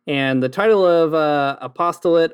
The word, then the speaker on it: Apost(le)ate: I pronounce it apostolate. It's sort of a mashup of the word apostate and apostle, as listeners may have already guessed.